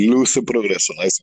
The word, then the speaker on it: Luz y Progreso: Am I saying that correctly? Light progress. – Yes, Luz y Progreso.